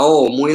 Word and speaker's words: Om: Y yo para llamarte, yo no digo, oye, sino que te llamo. Dice, pues el OM es igual no vas a decir AUM, sino que dices OM, no las letras, porque se pronuncia así.